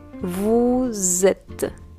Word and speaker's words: vous êtes: Pero en francés, cuando tenemos una S y después una vocal, vamos a hacer una cosa que se llama en francés liaison. Entonces vamos a pronunciar la S como si fuera una Z, una Z. Entonces vamos a decir vous êtes.